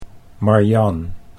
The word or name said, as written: Marjan